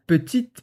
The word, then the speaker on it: petite: Et cette phrase nous aide à bien comprendre la prononciation de TE à la fin d'un mot, donc petite boîte. On prononce pas le E, mais on fait le T. Allez, répète après moi, petite boîte.